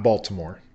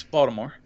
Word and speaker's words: Baltimore: You're having too many syllables in there. It's not Baltimore. – Yes, Baltimore.